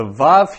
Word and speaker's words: vav: So this Right, the vav here, which is pronounced as a W or a V, and I pronounce it as a V, the, this can change a lot.